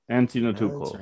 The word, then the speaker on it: Antetokounmpo: Then we got Giannis Antetokounmpo. – Antetokounmpo. Yeah, that's right. Yeah. Antetokounmpo. – Okay, well, I said it right.